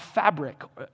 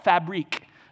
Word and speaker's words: Fabric: But I heard this Dana Spinola, who's the founder and CEO of Fabric. Some people call it Fabrique. A clothing store. Have you ever heard of that? She told us that it's actually pronounced fabric.